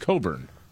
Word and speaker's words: Cockburn: And you're telling me his name is pronounced Coburn. – Correct. – Yes. – Okay. – The CK silent.